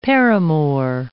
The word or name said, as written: paramour